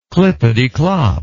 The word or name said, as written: clippetyclop